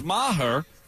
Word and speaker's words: Maher: Is that how you pronounce Maher? – Yep. – It was Maher, 52-yarder, drilled it.